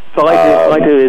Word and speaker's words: Baidu: Now, you had mentioned earlier Baidu, as I hope I'm pronouncing that at least partly correct. – Yes, Baidu is, as, yes.